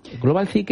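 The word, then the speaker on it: Globalthy: Global T, que se escribe global, como global, y luego THY, y que va a ser para todos ustedes, ¿eh?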